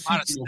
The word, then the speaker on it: Marsden: Y los otros personajes tenemos a James Marsden, Marsden, nunca sé cómo pronunciarlo, es Marsden. Pues.